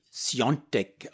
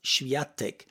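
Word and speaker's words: Świątek: Iga Natalia Swiatek geschrieben, spricht sich angeblich Sjontek aus. In Österreich höre ich allerdings dauernd Schwiatek.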